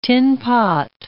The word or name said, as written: tin-pot